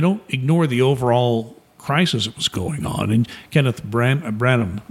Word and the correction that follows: Branagh: what's his name? Kenneth. – Right now, yeah. The. – Kenneth. – Yeah, Kenneth Branagh, you know, the Shakespearean actor. – Yeah, Branagh. Yeah. I'm mispronouncing names today.